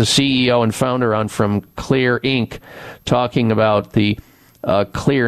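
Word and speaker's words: Xlear: My favorite way to do that is through using Clear Rescue Nasal Spray. The Clear is spelled with an X, The X in Clear stands for xylitol.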